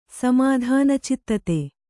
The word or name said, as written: ಸಮಾಧಾನಚಿತ್ತತೆ